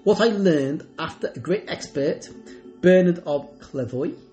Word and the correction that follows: Clairvaux: that Clairvoy is how you pronounce it, uh, C L A I R V A U X,